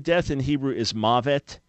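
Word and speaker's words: mavet (מוות): Yeah, M-A-H, ma, right? Then dash, V-E-T, mavet. Now, that's not a proper spelling, but that is how to pronounce it. M-A-H dash, V-E-T, mavet, death.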